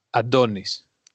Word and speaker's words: Antonis: It's, yeah, the second one. And it's Adonis.